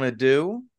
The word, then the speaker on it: adieu: I think, Matthew, it's time you go in, and I think it's time we bid everyone adieu. Is that how you say it? – Yes. – I bid you adieu.